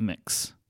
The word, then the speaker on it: Mx: The gender neutral version, which I have found out is Mix Claus, MX Claus, and I believe it's pronounced Mix.